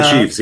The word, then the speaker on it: sheaves: Which means that if a sheev, is that right? If a sheev, is that I said that right? – Bringing the sheaves, yes.